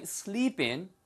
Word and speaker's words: sleep in: I might sleep in tomorrow. sleep in 발음이 sleepin처럼 들려요. sleepin 연결돼서.